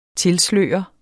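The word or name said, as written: TILSLØREDE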